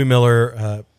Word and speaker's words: Mueller: But Felice, you and I did take a little road trip this last weekend to Louis Miller Barbecue in Taylor, Texas. And I know I'm saying Miller, it's spelled like Mueller.